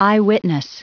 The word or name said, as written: eyewitness